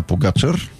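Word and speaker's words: Pogačar: Нали, това е само едно от многото му изключителни постижения на Погачър. Ние се спрехме на ударението на втората сричка, нали така? – Да.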